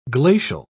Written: glacial

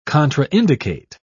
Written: contraindicate

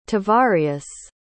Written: tavarius

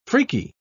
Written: freaky

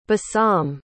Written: bassam

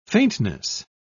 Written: faintness

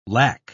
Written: lack

